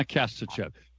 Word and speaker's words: Kasachev: Anna Kasachev. By the way, you, you know, I have said it correctly since you told me how to say it. Kasachev, like Chevy? – Chevy, but I drive a Ford.